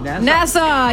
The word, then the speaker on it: Nassau: Anonymous listener in Nassau in New York. Did I say that right? – Nassau.